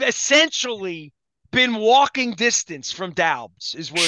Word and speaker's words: Doubs: So D-A-U, give me this again. – D-O-U-B-S. Daubs. – Daubs. – I don't even know how to say it. – It looks like it's missing an E. It should have a silent E.